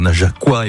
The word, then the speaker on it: Najaquois: Depuis 20 heures, on s'est pas mal baladé, même si on a fait un joli coup de projecteur au Najaquois et au Najaquoise. C'est bien comme ça qu'on prononce.